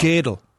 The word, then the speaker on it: Gödel: Gödel is how you actually pronounce it. Gödel.